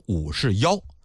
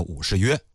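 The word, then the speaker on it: 武士彟: 当然这个字儿啊它也念获，有学者考证过，说按照唐音来念呢，应该念成武士妖，可是通俗来讲呢，史学界都管他叫武士约。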